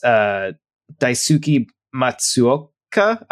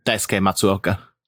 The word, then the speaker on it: Daisuke Matsuoka: And then they also have somebody credited for music and arrangement, who is Daisuke Matsuoka. I'm saying this wrong. – Daisuke Matsuoka. – There you go.